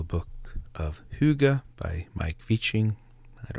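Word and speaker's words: Wiking: That's The Little Book of Hygge by Mike Viching. It's W-I-K-I-N-G. Even someone helped you with pronouncing, and I've said it wrong three times. But anyway, The Little Book of Hygge by Mike Viching, Danish Secrets to Happy Living.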